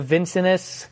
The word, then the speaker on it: Vincennes: We're just west of Vincennes. Thank you. I hate silent letters. Silent S. What are we doing with the silent S? This isn't France. This is USA.